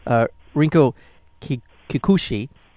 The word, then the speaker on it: Rinko Kikuchi: But now working with the actress who's terrific in this, well, and I know I butchered her name before, Rinko Kikuchi.